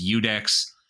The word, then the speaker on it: iudex: In a civil case, the praetor appoints what's called a iudex, spelled like judex, but it's Latin, so it's iudex, which is where the word judge comes from.